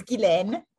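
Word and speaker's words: Guylaine: I said your name right, correct? I didn't say it right? – No, it's Ghislaine. It's Ghislaine St. Juice, and I serve as the president and CEO of NABA Inc.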